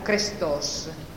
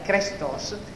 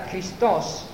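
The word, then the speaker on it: krestos (χρηστός): Ed è che qui buono, gustate e vedete quanto è buono il Signore, è con il termine greco non arcaico krestos, con la E, krestos, e siccome per un lungo periodo il greco veniva letto male, con una male, veniva letto, non si sapeva più come si leggeva il greco, e veniva letto con un difetto che si chiama itacismo, cioè leggendo i tutte le vocali, ovviamente lì si leggeva Christos, che è Cristo.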